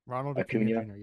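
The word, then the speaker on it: Acuña: At my three, this is going to make some Braves fans cream their pants, but I got Ronald Acuna Jr. Is that how you pronounce his name? – Ronald Acuna Jr., yeah.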